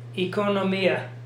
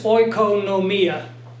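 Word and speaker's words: economia (οἰκονομία): The Bible school pronunciation, I think, is a false pronunciation that was developed by people who didn't know Greek. So they would call this oikonomia.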